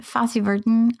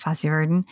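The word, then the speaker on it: Fosse/Verdon: And Fosse Verdon, is that how you say it? Fosse Verdon? – Yeah.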